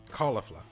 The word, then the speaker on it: Caulifla: Caulifla, caulifla. I'm, i think it's caulifla, right? Because it's supposed to be like cauliflower, so that sounds about right.